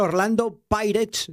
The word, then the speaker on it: Orlando Pirates: empate a un gol frente al Orlando Pirates.